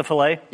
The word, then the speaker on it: filet: This strip of fish can have a double L and a sounded T. McDonald's prefers a single L and silent T. – What's a filet? – Yes.